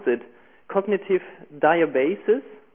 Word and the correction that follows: biases: Is it right? – Oh, biases? – And yeah. – Biases, yeah.